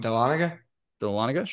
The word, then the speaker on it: Dahlonega: Dahlonega? – Sure.